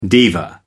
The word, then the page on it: diva: American English